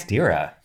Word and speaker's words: Kostyra: She was born Martha Helen Costira. I don't know if I'm saying that right. Costira. – Yeah. Kostyra in Jersey City, New Jersey.